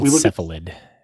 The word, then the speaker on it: Cephalid: It is included in a popular archetype called Silaphid Breakfast. – It's called Cephalid, by the way. – Huh? – It's Cephalid is how it's pronounced.